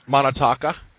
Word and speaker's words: Manitoba: Manitoba.